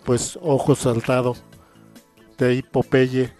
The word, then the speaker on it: Popeye: Y de hecho hay que mencionar también que originalmente en inglés no se pronuncia Popeye, sino Popeye.